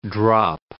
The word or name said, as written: drop